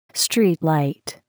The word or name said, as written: streetlight